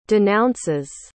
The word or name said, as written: denounces